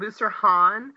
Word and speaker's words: Boussarhane: Um, my husband pronounces it Booster Han, but because I'm Southern and it was really weird to try to learn how to say it and spell it, I just call it Hainey because that's how I learned to spell it. But Hainey is fine.